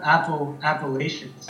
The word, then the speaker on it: Appalachian: No, just the way you say it makes me know, like, you say Apple, you know? Yeah. – Okay, how's it pronounced? – I mean, Virginians, are native virgins, I guess, if you want to interrupt like these guys, we call them Appalachians.